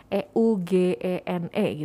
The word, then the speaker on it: Eugene: E U G E N E gitu kan dibacanya juga Eugene gitu.